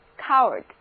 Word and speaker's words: coward: 注意这里的字母组合 AR 的读音是 a，跟我念一遍 coward。 OK，那今天呢我们是从信用卡这张小小的卡片说起， credit card。